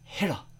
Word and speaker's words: Hiro: Honey Lemon, who insists on calling him Hiro. Giving it the Japanese pronunciation.